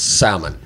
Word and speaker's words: salmon: You don't pronounce the L. – It's salmon? – Yeah. – Okay. – It's okay. I don't care, but I don't know if you're joking or not. – That's the way I say it. – It's incorrect. I want to save you further embarrassment. – Yeah. Salmon. Just pretend the L's not even there.